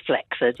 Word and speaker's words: flaccid: Is flaccid actually pronounced flaccid? – It is flaccid, yes.